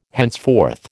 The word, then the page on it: henceforth: American English